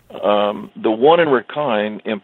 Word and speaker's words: Rakhine: It's pronounced Rakhine, as you told me before we got on the air.